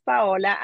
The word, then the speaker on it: Paola: My name is Paola.